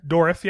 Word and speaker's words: Dourif: It's got fucking Brad Dourif in it. Did I pronounce his last name correctly? – Dourif. Yep.